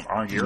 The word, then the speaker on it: Arngeir: Is that how you say his name? Arngeir? Mm-hmm. Sounds about right.